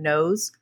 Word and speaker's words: Noyes: Um, I've heard his name pronounced a couple of different ways. He still has descendants that are alive today, and they pronounce her name Nose. Um, so I'm going to go with that.